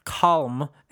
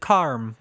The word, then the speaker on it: calm: He had some nice words to say about an episode, and then he specifically said, also, no one says calm in Australia. We pronounce it carm with an R.